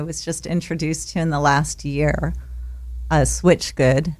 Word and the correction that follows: Switchfoot: I mean, Switch. Did I say that right? – Well, swim close. Good. It's Swim Good from Switchfoot.